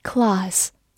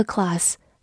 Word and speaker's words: class: class，c l a s s，class，字母 a 在英式发音当中读长音 r。 class，或者在美式读音中，字母 a 发大口 a，class 也是可以的。它是一个名词，表示班级、课或者阶级、种类。比如说，have a class 就是上课。